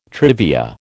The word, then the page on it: trivia: American English